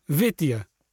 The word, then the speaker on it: Vitya: V-I-T-Y-A. Is that how you say it? – Vitya. – Very good.